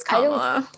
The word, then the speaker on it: Kamala: And yet somehow it's been spun into like, do you remember how do you pronounce the name? Is it Kamala Harris? – I think it's Kamala.